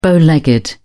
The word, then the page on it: bow legged: British English